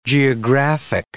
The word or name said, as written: geographic